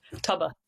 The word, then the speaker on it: tobar: or a tobar? Is that how you pronounce it? A tobar? – Tubba.